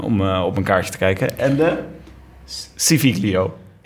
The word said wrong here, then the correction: Civiglio: Civilio. – Civilio. oh, ah, zo close, zo close met het goed uitspreken.